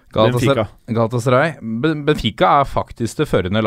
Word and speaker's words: Galatasaray: Galatasaray.